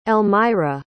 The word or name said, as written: Elmyra